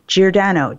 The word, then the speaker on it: Giordano: So my name is spelled L-O-R-R-A-I-N-E, Giordano, G-I-O-R-D-A-N-O.